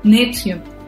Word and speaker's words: NXIVM: que es la secta Nexium, que de hecho se escribe NXIVM, nombre pretencioso a más no poder, y que esta secta no tenía ni fines religiosos ni espirituales.